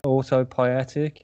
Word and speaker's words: autopoietic: autopoietic